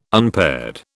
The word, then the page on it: unpaired: British English